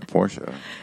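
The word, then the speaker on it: Portia: Fast forward to December, where she's been like, my game of the year is Time at Porsche.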